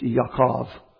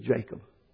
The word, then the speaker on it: Yaakov: Actually, it's Yaakov. We pronounce it Jacob.